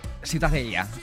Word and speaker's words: Ciutadella: ¿Tú cómo lo pronuncias? – Ciutadella. – Muy bien.